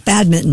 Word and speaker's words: badminton: No, we were talking about, I'm going to pronounce this incorrectly, badminton. You don't pronounce that N? – You do not. – No, badminton.